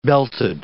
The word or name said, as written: belted